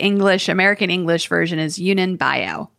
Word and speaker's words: Yunnan Baiyao: the Yanyan Bio. Uh, Yunnan Baiyao.